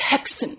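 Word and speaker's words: Häxan: The Swedish-Danish co-production of Haxan. I don't know if I'm pronouncing that right, actually. – Bo says it's Hexen.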